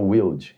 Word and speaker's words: Wilde: Well, let's say Wild, W-I-L-D. – Wild, you say? Yeah. – So we say Wilde. – Wilde. – There you go. – It's Wild. Wilde.